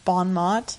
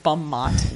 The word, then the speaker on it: bon mot: Is that how you pronounce it? – Bon mot? – Bon mot, it sounded like you said bum mot.